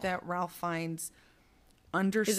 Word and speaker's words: Fiennes: Ralph Fiennes? Is that how you say it? – Fiennes, yeah.